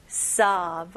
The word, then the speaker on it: sob: 주로 여기 오, 중간에 오 있는 단어들은 많은 한국 사람들은 이 입술을 사용해서 이렇게 오, 소, 뭐 이런 식으로 많이 발음하시더라고요. 근데 여기서는 입술은 사용하지 않고 그냥 턱만 벌리는 느낌으로 보세요. sob.